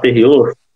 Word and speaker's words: Tereos: T-E-R-E-O-S. – Tem que falar Terrios, Terios, Tereos, enfim, mas hum.